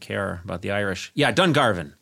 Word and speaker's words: Dungarvan: Conan, how do you say this? Dungarvan. – You barbarian. Yeah. – Yeah. I'm sorry. – It's okay. Dungarvan.